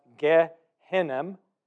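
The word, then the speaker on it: Gehinnom (גיא הינום): In Hebrew, it would be pronounced Gehinnom. That's Valley of Hinnom. In Greek, it's run together and it becomes the word Gehenna,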